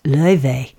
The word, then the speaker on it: Laufey: It's from a beautiful Grammy Award-winning Icelandic Chinese jazz singer whose name is spelled Laufey, but pronounced Leuve.